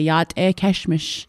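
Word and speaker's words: Yat'e Keshmish (Yáʼátʼééh Késhmish): So, and from Navajo Nation, they say, Yat'e Keshmish. That's how they say it.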